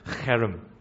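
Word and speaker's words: cherem (חרם): And that term is cherem. Okay, so it's H-E-R-E-M, but with that guttural sound. So the way you pronounce it is cherem.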